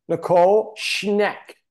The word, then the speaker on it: Nicole Schneck: But we would like to introduce Nicole Schneck.